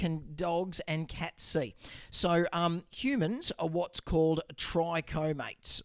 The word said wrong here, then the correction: trichromatic: But pardon my pronunciation. So trichromatic.